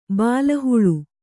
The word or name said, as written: ಬಲಾಹ